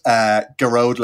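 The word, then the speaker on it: Gearoid: So I brought a show to the Edinburgh Fringe Festival and I called it Geroid Less Travelled.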